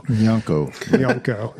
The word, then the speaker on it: Nyanko: Nyanko. – Like, yeah. – Gnocchi. – No, it's like, so he explained it to me, like, nya is like how Japanese imitate a cat sound.